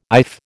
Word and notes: iff: American English

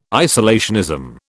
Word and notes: isolationism: British English

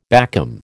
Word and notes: backcomb: American English

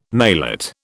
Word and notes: maillot: British English

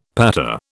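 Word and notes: pattée: British English

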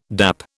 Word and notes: dap: British English

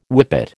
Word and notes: whippet: American English

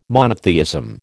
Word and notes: monotheism: American English

